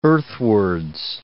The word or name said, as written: earthwards